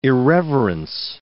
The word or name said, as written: irreverence